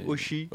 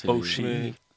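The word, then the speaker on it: O'Shea: Donc un O apostrophe plus loin, Shi, S-H-E-A. Oshi. – Oshi.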